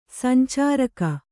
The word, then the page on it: ಸಂಚಾರಕ: sancāraka